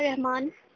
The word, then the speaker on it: Rahman: Talha Rahman, TALHARAHMAN.